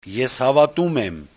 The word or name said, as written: eshavatumem